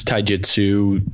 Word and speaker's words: taijutsu: You got anything taijutsu? Am I saying that correctly? – Yeah. I practice taijutsu.